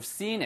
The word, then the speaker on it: seen: 치면 sin it. 이게 sin 이거는 뭐예요? 예, 죄를 저지르다 죄란 뜻이고 you should have seen it 그렇게 길게 발음하시면